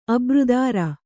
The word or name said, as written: ಅಬ್ರುದಾರ